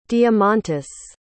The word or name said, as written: diamantis